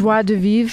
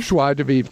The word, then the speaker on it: joie de vivre: Joy de vivre? How do you say that? – Joie de vivre. – Joie de vivre. Thank you.